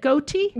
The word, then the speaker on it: Goethe: Goethe Institute in Berlin, and received her Bachelor's of Music in Vocal Arts from USC with a minor in Theater Arts.